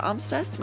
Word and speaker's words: Amstutz: And you can find all of my social media at Sandra Amstutz.